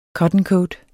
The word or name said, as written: cottoncoat